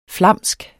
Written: flamsk

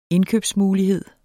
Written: indkøbsmulighed

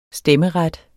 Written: stemmeret

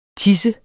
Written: tis

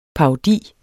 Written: parodi

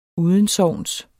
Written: udensogns

